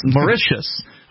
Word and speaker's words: Mauritius: Mauritius, which is officially the Republic of Mauritius, and it is number 19 on this list.